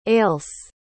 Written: ailse

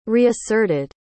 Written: reasserted

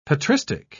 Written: patristic

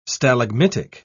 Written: stalagmitic

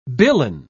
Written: billon

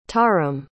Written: tarim

